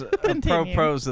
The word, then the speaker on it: apropos: Well, there's a propos of that one tweet we were talking about earlier. – Apropos. – How do you say that? – I think it's apropos.